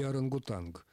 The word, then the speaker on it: орангутанг: И вот этот «анг», он как раз больше соответствует тому, как этот звук произносится в исконном языке. – Значит, можно и «орангутанг».